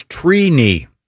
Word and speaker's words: Trini: The correct pronunciation is Trini. – Wow. Trini. – Like T-R-E-E. – Like weenie. – Yeah, I, and so is it phonetically T-R-E-E-K-N-I?